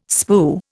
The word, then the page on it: spue: Indian